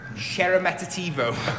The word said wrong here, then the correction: Sheremetyevo: I've pronounced that wrong every time I've said it so far. Sheremetyevo. Sheremetyevo.